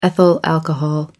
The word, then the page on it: ethyl alcohol: American English